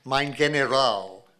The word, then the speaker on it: mein General: Liddy always referred to Mitchell as the Attorney General as mein General. Actually makes a point of that with a hard G.